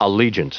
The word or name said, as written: allegiant